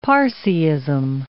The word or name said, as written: Parsiism